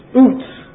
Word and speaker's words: Uz: Now, on the Hebrew word for Uz, translated Uz, is, those of you taking Hebrew will know or know anything about Hebrew, this is Utz. Easiest way to pronounce it, Utz. It's a long U.